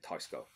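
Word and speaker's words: Teisco: A Tysco or Tesco. I'm just going to say Tesco because that's how everyone says it on the internet, but I'm going to pronounce it Tysco.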